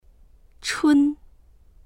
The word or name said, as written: chun1